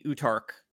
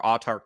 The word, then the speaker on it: Autarch: So we mentioned before that the Utarch, or Autarch, depending on how you pronounce it, is a god figure.